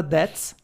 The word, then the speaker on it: That's: Aí nós temos o Honda Dats. E se escreve exatamente como vocês pensam em inglês. De tipo, T-H-A-T, apóstrofo S. Tipo, Dats, Dats.